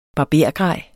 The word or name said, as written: BARBERGREJ